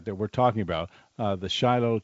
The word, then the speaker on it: Shiloh: In Israel we call it Shiloh. That's the Hebrew pronunciation.